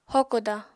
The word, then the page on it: hokoda: apatani